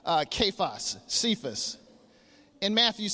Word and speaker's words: Cephas: We pronounce it Cephas. Actually, it's pronounced Kephas in Aramaic, or Peter in Greek, which means stone in both languages.